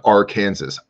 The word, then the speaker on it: Arkansas: It's pronounced Arkansas.